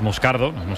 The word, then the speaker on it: Moscardo: Entonces, sí, como bien ha pronunciado Unai, es Moscardo, es brasileño, sí.